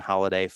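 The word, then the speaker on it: Holliday: It's just Jason Holiday 545.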